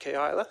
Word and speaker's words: Keilah: It starts in the beginning where David hears word that those pesky Philistines have come and they're raiding the threshing floor in, how'd you pronounce it? Keilah? That works. We'll go with that, Keilah.